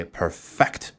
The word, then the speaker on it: perfect: 注意它的读音，一般是把重音放在末尾的这个音节 perfect，这样才有一种发力的感觉，才有一种动词的感觉，对不对？